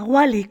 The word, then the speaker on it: Wally: Así concluye nuestro recorrido por la Bali, o la Wally, como prefieran ustedes pronunciarlo,